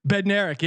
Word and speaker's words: Bednarik: How you say his name? Bednarik? – Bednarik, yeah.